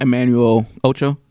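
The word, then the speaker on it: Emmanuel Acho: The host, I know I'm saying his name wrong. – Emmanuel Ocho? – That's how you say it.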